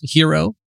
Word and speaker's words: gyro: Or, you know, sometimes a gyro, which they would pronounce hero. Is that right? – Yep.